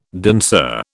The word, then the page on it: danseur: British English